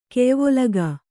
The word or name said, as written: ಕೆಯ್ವೊಲಗಾ